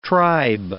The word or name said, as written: tribe